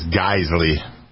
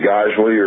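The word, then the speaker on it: Geissele: G-I-S-S-E-L-L-E. – I always pronounce that as Geisley. – Okay, Geisley, or, yeah, I don't know, Geisley.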